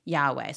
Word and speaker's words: Yahweh: So in between YHWH, an A and an E are inserted to help us pronounce Yahweh.